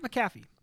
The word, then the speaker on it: McAfee: Oh, Pat McAfee.